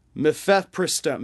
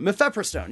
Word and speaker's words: mifepristone: because mifesperone, uh uh, uh, uh, I forgot how to say it. I'm so sorry. Um, mifepristone, mifepristone, yeah, mifepristone has been on the market for 20 years.